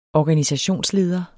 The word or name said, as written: organisationsleder